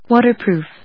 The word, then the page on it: WATERPROOF: wa・ter・proof; wˈɔːṭɚprùːf; American English; wˈɔːtəprùːf; British English